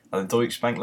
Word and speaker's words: Deutsche Bank: I think the Deutsche Bank logo, is that pronounced right? – Yeah.